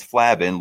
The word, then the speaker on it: Flavin: So for those who don't know how to say it, it's not Flavin, it's Flavin, like flaccid.